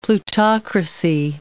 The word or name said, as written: plutocracy